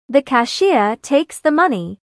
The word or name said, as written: cashier